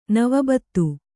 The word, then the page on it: ನವಬತ್ತು: kannada